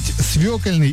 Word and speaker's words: свекольный: Как правильно говорить, свекольный или свекольный? – Свекольный. – Правильно.